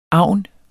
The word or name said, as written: agn